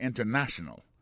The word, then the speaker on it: international: Par exemple, en français, on dit international. En créole, c'est international. Mais en anglais, la différence, c'est international. Ouais, accent tombé sous na.